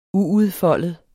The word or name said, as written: uudfoldet